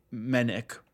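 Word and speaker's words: Menuck: It's M-E-N-U-C-K, Menek.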